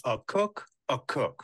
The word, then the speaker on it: Akok Akok: All right, but while you're waiting for that to load, I will let you know Akok Akok. That's pronounced a cook a cook.